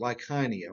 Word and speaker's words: Lycaonia: I think that's how I looked it up and listened to the pronunciation online. That's how you pronounce it, Lycaonia, although I used to say Lycaonia.